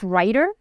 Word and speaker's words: Rytr: So, I mean, I've tried and worked with things like what is called RYTR. So it's pronounced writer, which I also use.